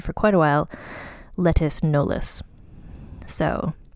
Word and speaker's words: Knollys: So her last name is Knollys, or it's spelled K-N-O-L-L-Y-S, and I believe it's pronounced Knollys, like the same way as Beyonce Knollys Carter.